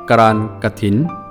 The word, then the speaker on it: กรานกฐิน: สวัสดีครับพบกับรายการคำวัดสำหรับวันนี้เสนอคำว่าการกระถินคำว่าการกระถินสะกดด้วยกอไก่รอเรือสระอานอนหนูกอไก่ทอสันฐานสระอินอนหนูสะกด